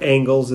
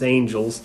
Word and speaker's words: Angels: I mispronounced it before. It's not Angles, it's Angels.